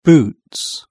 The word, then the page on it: boots: British English